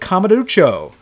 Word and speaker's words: Kamurocho: It uses the same engine as Yakuza 6, features insane street brawls, and is even set in the fictional district of Kamurocho. Oh god, I hope I said that right. – Nailed it.